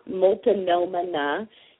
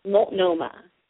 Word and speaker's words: Multnomah: but it's not Multnomah, it's Multnomah. Three syllables, Multnomah County, okay?